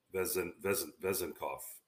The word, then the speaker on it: Vezenkov: lack thereof of scoring efficiency for Sasha Vezenkov. I always am trying to see. Did I say that right? Vezenkov? – Yeah, you got it.